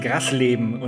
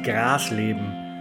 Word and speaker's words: Grasleben: Zuerst einmal, es heißt Grasleben und nicht Grasleben. Genau wie Helmstedt nicht Helmstedt ausgesprochen wird.